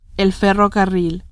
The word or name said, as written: ferrocarril